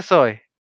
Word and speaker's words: Soen: Que se pronuncia Zoe.